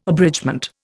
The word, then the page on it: abridgement: Indian